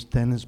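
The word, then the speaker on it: Denis: My name is Dennis Balance.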